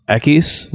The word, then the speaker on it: equis: Latinx is fucking stupid because X would be pronounced Equis, like Dos Equis.